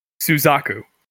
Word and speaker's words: Suzaku: And how do you pronounce the name of the main character's best friend in the acclaimed and widely known and best show of all time anime show Code Geass? – Suzaku. – Oh my god, you said it right!